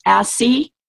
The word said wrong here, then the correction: ASEA: It's pronounced ASEA. – ASEA.